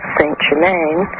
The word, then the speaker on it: Saint-Germain: So Louis gave him the title Count of Saint Germain, in French pronounced Saint-Germain,